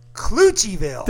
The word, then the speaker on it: Cloutierville: But it's not Cloutierville. – No, it's not Cloutierville. – And it's taken me quite a long time to figure out how to say it after several people. – So go ahead and say it. Say it. – Cloutierville. – Thank you. – So Cloutierville is Cloutierville. – It's like Hooterville.